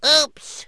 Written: oops

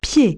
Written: foot

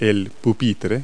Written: desk

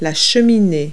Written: stack

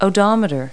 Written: odometer

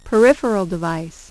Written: peripheral device

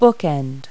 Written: bookend

